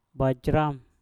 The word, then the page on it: bajram: mpur